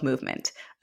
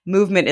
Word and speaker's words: Moovement: For those who are just listening, Moovement is spelled M-O-O-V-E-M-E-N-T. So how did you come up with the name? – I like to say the second O is silent, when so you don't have to pronounce it.